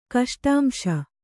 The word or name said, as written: ಕಷ್ಟಾಂಶ